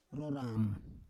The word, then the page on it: roram: Roon